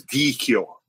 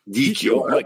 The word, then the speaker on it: Dicchio: Okay, so CH in Italian is key. So it's Dicchio. Dicchio. No, it is. It's only because my dad told me that. Because in England, they pronounce CH differently. It's ch.